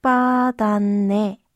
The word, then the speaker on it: 복받았네: Das würde dann lauten popadaneyo. Lassen Sie uns aber gleich noch einmal die Aussprache der nicht höflichen Form aus der Szene zusammenüben. Sprechen Sie bitte nach Pok 복받았네. Ich wiederhole. 복받았네.